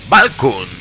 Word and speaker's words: Valknut: Es una banda llamada Valknut. Se escribe V-A-L-K-N-U-T.